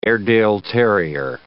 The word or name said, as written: Airedale terrier